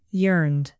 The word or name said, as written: yearned